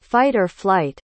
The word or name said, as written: fight-or-flight